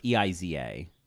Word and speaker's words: Eiza: Now, I think her is it Eiza? Not, I'm probably pronouncing it wrong, but it's Eliza? – No, it's Eiza.